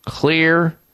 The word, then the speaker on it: Xlear: Crack out the Clear Nasal Spray. Clear is spelled X-L-E-A-R because the X stands for that one of the magic ingredients in there, the main one, xylitol.